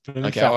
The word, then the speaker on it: Blumenfeld: You're going Blumenfeld, and it's Blumenfeld. – Felt. – He was very disgruntled. We'll come to that later on in the discussion of the week. He did a post and said, get your shit sorted out. – Blumenfeld. – Pronounce the name correctly. – Blumenfeld. Okay, I promise. I'll say felt, not field.